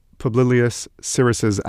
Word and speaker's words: Publius Syrus: I'm reminded here of Publius Syrus's adage, and yes, I did pronounce that correctly.